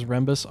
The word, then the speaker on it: Rembis: Last name is Rembis, R-E-M-B-I-S. They spelled it Remus, R-E-M-I-S.